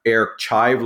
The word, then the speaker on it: Airchive: Thank you for pronouncing the site correctly, because we do get the pronunciation Airchive like an onion. I can assure you it is not an onion.